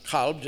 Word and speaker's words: kalb: Another possible Hebrew etymology is Hebrew kalev, dog, originally pronounced kalb, just as it is in Arabic.